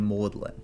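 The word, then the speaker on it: Magdalen: Yeah, so it seems like in the 1400s and the 1500s, in Europe, the G in Magdalene was essentially not pronounced. – Okay. – So these colleges, it's M-A-G-D-A-L-E-N, and the other one has an E on the end. But they don't pronounce the G. They're Maudlin.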